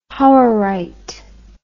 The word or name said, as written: hauerite